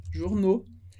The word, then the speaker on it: journaux: A pronúncia sempre, sempre vai ser o. Então, le journal, les journaux. Journaux.